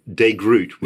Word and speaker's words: De Groot: How do you say that guy's name? – Well, well, depends on what language is, but De Groot.